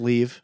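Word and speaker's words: Liev: Leave Schreiber, is that where we've landed? I've heard it pronounced so many different ways on different podcasts that I always think whatever I'm about to say is the wrong one. – Yeah, I think it's Leave. I'm pretty sure.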